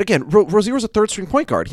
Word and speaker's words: Rozier: it's Rozier. That's how all these guys have always been pronounced.